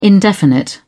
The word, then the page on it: indefinite: British English